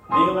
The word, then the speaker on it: Kneel: Kneel at the cross. All right. The K is silent.